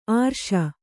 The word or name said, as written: ಆರ್ಷ